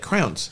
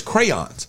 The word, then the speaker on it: crayons: The word is crayons, like Crayola, crayons.